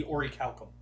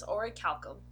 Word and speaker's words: orichalcum: Because I found out that's not actually how you pronounce it. – It's supposed to be orichalcum. – Yeah. – What the bullshit? – Yeah. – Yep, I looked it up online after they mentioned it in Dragon Quest, and it is orichalcum. – Because it is an actual Greek word.